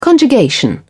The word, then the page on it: conjugation: British English